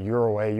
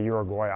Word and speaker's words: Uruguay: That's how they pronounce Uruguay, Uruguay. I usually just say Uruguay, but that's in like uh, South America, in between Argentina and Brazil.